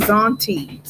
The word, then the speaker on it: Zaunte: His name is Zaunty. So it's spelled Zaunte.